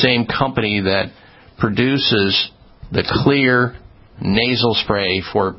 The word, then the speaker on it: Xlear: Clear Max Nasal Spray. Clear is spelled X-L-E-A-R.